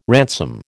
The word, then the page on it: ransom: American English